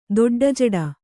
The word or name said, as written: ದೊಡ್ಡಜಡ